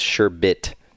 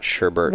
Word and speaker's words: sherbet: Most experts agree that the correct pronunciation is sherbet. Sounds like hermit, not sherbert.